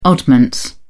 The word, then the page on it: oddments: British English